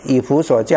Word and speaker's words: 以弗所: 好，所以第一个以弗所教会啊，这个弗这个字是姓弗的弗，读音是弗，知道吧？你们打字的时候是打弗，我们不要念错哈，以弗啊，以弗所教会，右手拿着七星在七个金灯台中行走，所以他强调的是他是管理教会或者是掌管教会啊，运行教会的那一位。